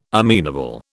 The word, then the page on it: amenable: British English